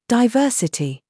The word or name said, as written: SAUCE